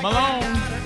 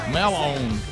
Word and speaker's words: Melown: Ken and Carton Mellon, where are they at? Right there, right there. Malone. It's spelled odd. They said the family spells it M-E-L-O-W-N. Malone. Malone.